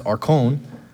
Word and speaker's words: archon: But the magistrate, the word, the Greek word for that is pronounced archon.